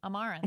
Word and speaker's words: Amaranthe: A-M-A-R-A-N-T-H-E. Amaranth. – Amaranth. – I think that's how you say it. Yes, Amaranth. I was adding way too many syllables in there.